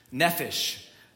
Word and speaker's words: nephesh: Nephesh. That's what it looks like if you were to spell it with English alphabet. But you say it nephesh, like an F sound.